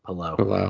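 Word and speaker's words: Palou: By the way, that's the proper pronunciation of his name, Palau.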